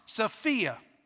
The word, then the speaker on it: sophia: and it's pronounced Sophia.